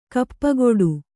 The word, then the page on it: ಕಾಪುಗಾಡು: kāpugāḍu